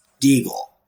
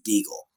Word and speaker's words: Diggle: So my name is pronounced Deagle. That is actually how you pronounce it.